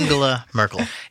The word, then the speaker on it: Angela Merkel: Angular Merkel. – Angela Merkel.